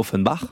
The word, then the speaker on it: Offenbach: Ça peut se prononcer en français, en allemand, Offenbach.